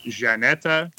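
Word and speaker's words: Żaneta: So you'll probably see her name is spelled Zaneta. For those of you that can hear me now, it's pronounced Janeta.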